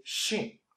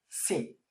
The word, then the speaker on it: Shin: We have to pay attention to say Shin Buddhism, not Sin Buddhism.